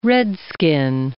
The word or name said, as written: redskin